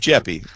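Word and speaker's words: Jeppe: And Mr. Jeppy, I hope I'm saying his name right, J-E-P-P-E. I believe it's Jeppy, right? – Mm-hmm.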